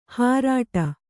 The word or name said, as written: ಹಾರಾಡು